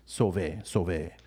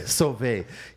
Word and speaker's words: Sauvé: So he's an avid listener of ours at Sove Creates, which is spelled S-A-U-V-E. Sove. Okay? – Sauvé.